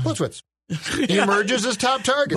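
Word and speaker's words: Plitzuweit: If you say Plitzwit real fast, Plitzwit. – You can't mispronounce it.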